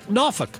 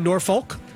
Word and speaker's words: Norfolk: Like when we talk about Norfolk. – Right. – I'm not saying Norfolk. – Right. – I'm saying Norfolk.